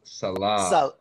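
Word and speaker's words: Saleh: I think it's pronounced Salah. – Salah.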